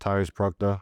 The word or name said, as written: Tyrese Proctor